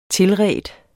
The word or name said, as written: tilredt